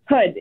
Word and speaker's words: Houde: Just how do we, how do we say your last name, Melissa? – Hood, H O U D E, Hood.